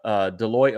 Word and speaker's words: Deloitte: So coming from a report from the Deloitte officials, I hope I said that.